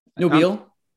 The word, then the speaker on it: Nobile: Nobile. Like mobile? – Like how it's spelled? – Batmobile? – Well, exactly. Okay, right, it's like, that eye is invisible. People have face blindness for that eye in there, I swear to God.